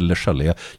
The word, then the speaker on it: Le Chalet: Den heter, här är jag faktiskt, vilket är väldigt ovanligt, mer säker på det franska uttalet. Det heter Le Chalet. Jag tror man säger The Chalet på engelska, men jag är inte riktigt säker. – The Chalet.